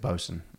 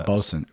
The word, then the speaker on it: boatswain: It's we pronounce it bosun. It's the bosun.